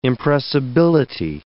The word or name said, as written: impressibility